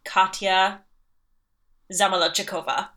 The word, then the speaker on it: Katya Zamolodchikova: But my favorite queen of all the queens of all the seasons is one Katya Zamolodchikova.